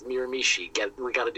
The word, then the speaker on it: Miramichi: So we had heard through the grapevine that NWE out in Miramichi, Anthony, I'm looking at you to correct my pronunciation. – You got it. – Butchered that. I got it on that one. – Yeah, even I know that one. You nailed that one.